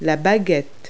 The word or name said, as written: stick